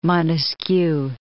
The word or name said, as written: Montesquieu